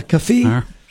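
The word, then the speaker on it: keffiyeh: Kefis or Kefias. – Kefi. – Kefi. – There it is. It's Kefi. It's Kefiyeh, a Kefi, which is the same thing you see the Palestinians wear.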